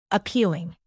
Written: appealing

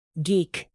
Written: geek

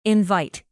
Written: invite

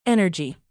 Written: energy

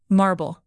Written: marble rock

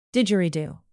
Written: didgeridoo

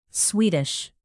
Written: swedish

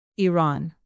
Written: iran

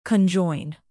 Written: conjoin

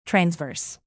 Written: transverse

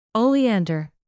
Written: oleander

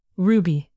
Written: ruby

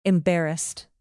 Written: embarrassed

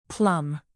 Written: plum

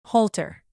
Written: halter

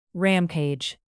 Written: rampage

